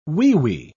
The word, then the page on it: weewee: British English